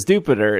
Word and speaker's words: Dupiter: But for whatever reason, Dupiter is Dupiter. It's supposed to rhyme with Jupiter.